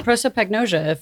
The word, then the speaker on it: prosopagnosia: Prosopagnosia, from what I understand, it's pronounced.